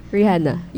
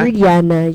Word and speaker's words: Rihanna: Again, am I saying it correctly? Is it Rihanna? – Rihanna, yes.